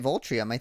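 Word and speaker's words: Viltrum: I think that's right, Voltrium. I think that is exactly the way it's pronounced.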